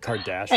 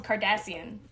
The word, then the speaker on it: Cardassian: The race of aliens is called Cardassian. – Okay. He's Kim's nerd. Uncle. – It's spelled with a C, not a K.